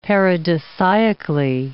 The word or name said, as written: paradisiacally